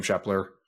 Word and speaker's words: Shepler: It's just at Sam Shepler. And my name is exactly how it's spelled.